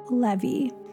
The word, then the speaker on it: Levy: Starting in 2020, I began making Halloween songs and music videos under the artist name Levy, pronounced like the man-made dam-like water thing in cities.